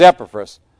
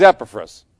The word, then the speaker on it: Epaphras: There's different ways to pronounce that, but that's the way you're going to hear me pronounce it. All right, so there it is. I've heard about 4 different versions of that. So for us, he's Epaphras.